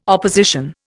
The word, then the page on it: opposition: Indian